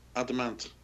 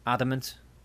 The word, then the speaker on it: Adamant: There was the interview there with Adamant. – No, not Adam Ant. He's from the 80s. – Adamant. Yeah. – Adamant. Just say it like a word, Adamant.